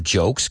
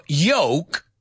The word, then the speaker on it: joke: Because if you don't say, because the word is joke. Dilo conmigo. Joke. Joke. Joke. Right? Joke. Como CH, pero no realmente. Joke. It's a joke. Porque si no lo pronuncias bien, estás diciendo yoke, Y-O-K-O-K-E, que es yugo.